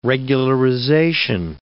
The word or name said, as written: regularization